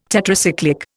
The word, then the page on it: Tetracyclic: Indian